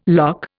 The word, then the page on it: lough: Indian